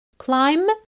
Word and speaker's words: climb: Climb يعني يتسلق. ناخد بالنا أن البي في climb لا تنطق. It's silent. Climb.